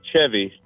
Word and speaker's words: Cheve: How do you say your name? – It is Chevy. – Chevy. Not Chevy. See, when I see it spelled out, it's C-H-E-V-Y, correct? – Yeah, C-H-E-V-E. – E-V-E.